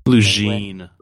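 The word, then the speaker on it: Lugene: how do you say it? Linguine? – Lejeune. – Lejeune Chocolate Milk Stout.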